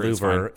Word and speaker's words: Louvre: Louvre. It's just pronounced Louvre. Louvre.